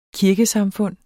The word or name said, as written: kirkesamfund